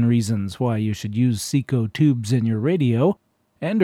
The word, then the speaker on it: CECO: The band we just heard was Merle Johnston and his Seco Couriers, and they were sponsored on radio by the Seco Manufacturing Company of Providence, Rhode Island. Seco is spelled C-E-C-O with both of the C's capitalized.